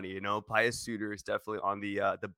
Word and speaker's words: Pius: It's pronounced Pius.